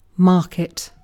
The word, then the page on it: market: British English